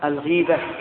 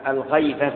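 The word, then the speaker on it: الغِيبة: الغيبة فعلة من الغيب، وليست كما ينطقه بعض الناس الغيبة بالفتح، هذا لحن محيل للمعنى.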